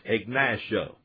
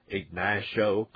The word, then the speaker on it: Ignacio: That's Post Office Box, capital P, period, capital O, period, capital B-O-X, and then the number 825. On the next line, you would put Ignacio. That's spelled capital I-G-N-A-C-I-O. That was the name of a Southern Ute chief. Ignacio, capital I-G-N-A-C-I-O, comma, then capital C, capital O, period.